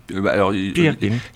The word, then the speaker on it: Peer Gynt: C'est une musique pour une pièce d'Henrik Ibsen qui s'appelle, alors ça je ne sais pas le prononcer, Peer Gynt ou Peer Gynt. Peer Gynt. Les puristes ils disent Peer Gynt.